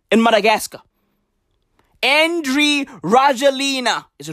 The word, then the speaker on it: Rajoelina: Bruv, Andre Rajoelina looks 12.